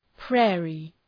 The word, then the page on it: prairie: ‘preərı